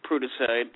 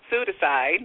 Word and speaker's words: Pseudicide: Right, and also you have Prudicide, if I'm pronouncing that right? – No, Pseudicide. Nope, the P Pseudicide. Is silent, like in pneumonia and pseudonym.